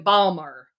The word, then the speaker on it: Baltimore: I should say stooping is a verb in Balmer. – Balmer. Because Balmer. – That's how it's pronounced. – Balmer. – Baltimore is Balmer.